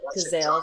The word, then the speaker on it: Cazale: Yeah, Cazale. That's how you pronounce his name.